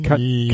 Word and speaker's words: Kanye: Und Kenny West spricht man Ken, Kanye. Kanye. Also Kanye.